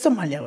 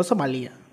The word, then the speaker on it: Somalia: Pues, es que es Somalia, güey, no Somalia. No entiendo por qué luego la gente le dice Somalia. O sea, se escribe Somalia, güey, no tiene acento en ahí.